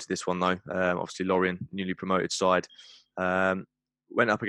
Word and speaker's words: Lorient: Is it Lorient or? – Yeah, Lorient.